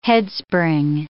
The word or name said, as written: headspring